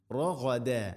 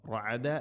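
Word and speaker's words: റഗദ (رغدا): റഗദ റഗദ ഗൈന് തൊണ്ടയുടെ മുകൾ ഭാഗം റഗ റഅദ അല്ല റഗദ. റഗദൻ വദ്ഖുലു തൻവീന് ശേഷം വ വന്നാൽ ഇദ്ഗാം ബിഗുന്ന റഗദൻ വ മണിച്ചുകൊണ്ട് ഉച്ചരിക്കണം, തൻവീനിനെ വയിലേക്ക് ഇദ്ഗാം ചെയ്യണം.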